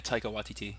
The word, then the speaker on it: Taika Waititi: I mean, you see someone like forgive me if I pronounce it a little, but Taika Waititi. – Taika Waititi, I believe.